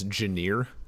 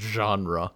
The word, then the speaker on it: genre: I think my biggest one in general for like actual words was I would pronounce genre as Janeer, because it was one of those things where I knew what it meant, I knew what it was, but somehow no one had ever said it around me. Like, no one had ever said that word. And you look at it, like, how am I going to fucking know it's pronounced genre?